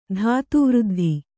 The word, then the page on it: ಧಾತುವೃದ್ಧಿ: dhātu vřddhi